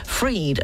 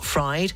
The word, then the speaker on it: Fried: And the F FTX founders Sam Bankman Freed and not Fried, as I apologise for repeating and mispronouncing his name earlier on in the show, has been arrested in the Bahamas.